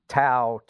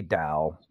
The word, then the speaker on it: Dao: We don't have to get into tones and all that, but in Chinese you would also use a particular tone when you say Dao, which would sound like Dao.